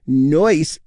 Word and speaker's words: Neuss: And yes, Neuss, N-E-U-S-S, is pronounced Neuss.